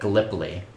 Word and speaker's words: Gallipoli: How do you say that? – Gallipoli.